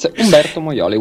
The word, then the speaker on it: Umberto Moioli: It's Umberto Moioli, which sounds um, really Italian.